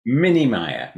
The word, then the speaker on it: Minemier: Minemier.